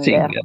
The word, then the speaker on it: Singer: Singer.